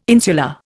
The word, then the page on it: insular: Indian